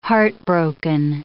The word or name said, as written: heartbroken